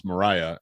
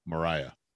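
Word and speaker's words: Murraya: So, like, I didn't realize it was pronounced Mariah until I was getting ready for this, and it's M-U-R-R-A-Y-A, Mariah.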